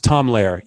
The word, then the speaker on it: Lehrer: I think it's pronounced Tom Lehrer.